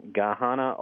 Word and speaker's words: Gahanna: And one of the latest examples, Chuck, involves this pricey pre-K to 12 school called Columbus Academy in Gahanna, Ohio, if I'm pronouncing that correctly. – Yeah.